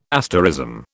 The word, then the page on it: asterism: British English